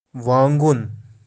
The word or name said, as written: waangun